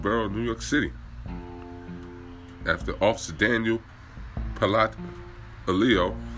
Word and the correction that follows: Pantaleo: Pantaleo, that's how you say his name, I guess.